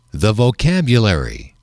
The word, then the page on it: the vocabulary: male